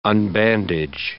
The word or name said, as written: unbandage